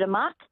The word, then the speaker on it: amok: It's pronounced amok in Indonesia, but a lot of Westerners use the word amok.